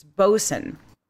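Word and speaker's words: boatswain: Same with boxswain. It's bosun.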